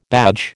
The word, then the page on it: badge: American English